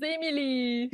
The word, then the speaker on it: Émilie: Now, Émilie, I'm terrible with French, so she'll just have to let you guys know how it's pronounced here in a second. – My name is Émilie.